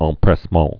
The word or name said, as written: empressement